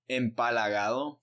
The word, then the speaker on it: empalagado: How do you say that? – Empalagado.